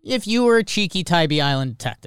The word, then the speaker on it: Tybee Island: Oh, it's how do you pronounce it? Savannah? Tybee Island? Isn't that in Savannah? – Tybee Island, yeah.